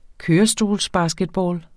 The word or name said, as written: kørestolsbasketball